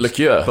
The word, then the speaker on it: liqueur: I've just realised, it's spelled Pussy Liquor as in like pussy booze. – Liqueur. – But also, liqueur.